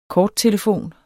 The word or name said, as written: korttelefon